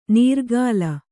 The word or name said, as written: ನೀರ್ಕಲ್